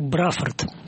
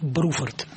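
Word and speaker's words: Bruford: И называли этого человека Бруфорд, в то время как правильно его называть Бруфорд.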